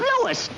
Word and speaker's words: Louis: Sounds like there might be a story at the plant, Lois. – Lois? Me name is Lewis, not Lois. Gee whiz, everybody in Troy believes me name wrong. It's Lewis. L-O-U-I-S.